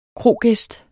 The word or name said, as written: krogæst